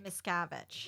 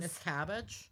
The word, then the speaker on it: Miscavige: Miscavige. – What? Whatever. – I like the way you say Miscavige. – It's like Savage, but Miss. – Voorhees. – Miscavige.